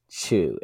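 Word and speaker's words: Xu: Chris Chu, and it's spelled X-U, that's supposed to be CEO Sheehan.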